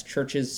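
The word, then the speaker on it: CHVRCHES: That's Church's spelled with a V, C-H-V-R-C-H-E-S.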